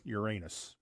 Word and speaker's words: Uranus: And yes, the town's name is pronounced just as any class clown would say it, Uranus. That's the town. – Yeah. – And like the planet name.